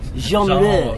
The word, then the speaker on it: genre: Actually, I'm fluent in French. You guys don't know that. – Genre. – It's a soft G. – thank you for that. Genre.